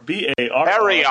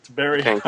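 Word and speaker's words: Barrayar: First is, my wife keeps correcting me, and now I don't know if I'm saying it right. – Spell it. – It's Barrier, B-A-R-R. – Barrier. – All right, Barrier. Okay.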